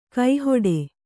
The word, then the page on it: ಕೈಹಿಡಿ: kai hiḍi